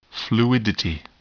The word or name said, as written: fluidity